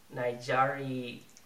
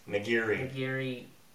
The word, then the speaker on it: nigiri: Samshimini Nigari. – Nigiri.